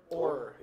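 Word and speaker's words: Oher: Orr, yeah, Orr.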